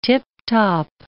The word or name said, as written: tip-top